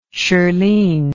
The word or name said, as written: Shirleen